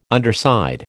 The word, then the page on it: underside: American English